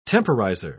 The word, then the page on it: temporizer: British English